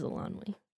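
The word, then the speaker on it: Eilonwy: This would be Alanwi, I think is how you pronounce that. – Yes, it is Alanwi.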